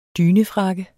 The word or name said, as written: DYNEFRAKKE